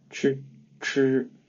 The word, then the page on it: 吃: Mandarin Chinese; ch